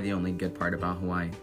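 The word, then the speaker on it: Hawaii (Hawaiʻi): When you say Hawaii, there's an okina between the two I's, so it's not like Hawaii. – My sister dragged me into this, help me. – And W's are pronounced as V, so it's like Hawaii, because there's okina.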